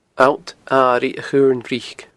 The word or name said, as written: Allt Airigh a Chuirn Bhric